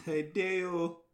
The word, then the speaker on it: Hideo: Hideo, goddamn, I'm saying his name wrong, but Hideo Kojima. – It's Hideo.